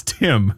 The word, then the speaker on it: Tihm: I made a character for a D&D campaign, and I put T-I-H-M. And someone asked, like, how do you say that? I'm like, how do you think? It's Tim.